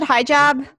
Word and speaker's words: hijab: Oh, I was trying out a hijab. Is that how you pronounce it? Hijab? – Hijab, Mike, yeah.